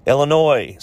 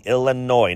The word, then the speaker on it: Illinois: Number five, Illinois. Say it correctly, Illinois, not Illinois.